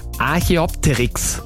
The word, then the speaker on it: Archaeopteryx: Ich würde jetzt nochmal den den muss ich mir mal konzentrieren bei der Aussprache, Archaeopteryx. War das richtig? – Ja. Ja, das klingt gut.